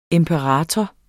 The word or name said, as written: imperator